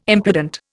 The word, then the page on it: impudent: Indian